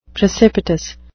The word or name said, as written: precipitous